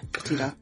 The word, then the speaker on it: Petito: Petito? – Yeah, Petito.